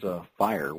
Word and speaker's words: FHIR: I think what's really starting to catch on is FHIR, which is spelled F-H-I-R, Fast Healthcare Interoperability Resources is what it stands for.